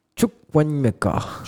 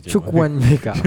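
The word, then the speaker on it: Chukwuemeka: Juste dis-moi bien la prononciation. Prononcez le nom. Tu as le truc là Ok. Il des titulaire, je crois. Chukwemeka. Chukwemeka. Moi Tueku, moi. – Chukwemeka.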